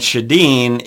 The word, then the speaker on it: Sjodin: Drew Shadeen, it's D-R-U, Drew, and Shadeen is S-J-O-D-I-N.